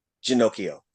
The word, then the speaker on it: Ginocchio: Without further ado, Janae Ginocchio. Did I say it correctly? – It's Janie. But Janie. Yes, you got the last name.